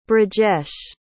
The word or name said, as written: Brijesh